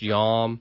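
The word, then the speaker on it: yom (יום): And I believe it's the Hebrew word is pronounced yom,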